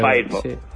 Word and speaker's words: Firefox: Firefox. Por cómo se escribe. – El zorro de fuego. – Se dice Firefox.